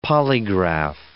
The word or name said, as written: polygraph